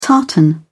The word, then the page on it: tartan: British English